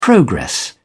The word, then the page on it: progress: British English